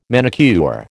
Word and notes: manicure: American English

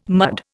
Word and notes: mud: Indian

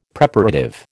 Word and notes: Preparative: American English

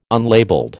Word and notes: unlabelled: American English

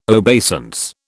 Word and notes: obeisance: British English

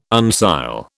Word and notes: uncial: British English

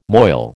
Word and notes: moil: American English

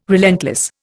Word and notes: relentless: Indian

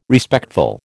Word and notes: Respectful: American English

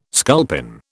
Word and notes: sculpin: British English

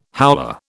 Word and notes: howler: British English

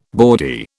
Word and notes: bawdy: British English

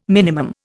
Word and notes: minimum: Indian

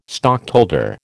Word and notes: stockholder: American English

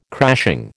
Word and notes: crashing: American English

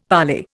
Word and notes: parley: Indian

